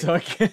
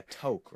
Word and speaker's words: toque: Bro said like, Took. – I'm pretty sure I've done that before on the podcast, too. – If anything, you call it like a toque, right? By how it's spelled. But it's Took.